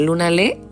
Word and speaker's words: Lunalé: Bueno, Lunalé se escribe todo pegado, como suena, Lunalé. Con tilde en la E.